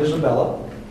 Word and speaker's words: Isabella: Again, my name is Don Isabella. Can you misspell that? – Yes. – I-S-A-B-E-L-L-A.